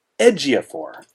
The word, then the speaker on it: Ejiofor: His name, by the way, his first name is pronounced Chiwetel. – Chiwetel. – His last name is pronounced Ejiofor. – Ejiofor.